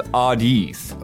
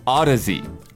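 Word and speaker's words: Audeze: It's not pronounced Audiz. – Oh. – It's pronounced Odyssey.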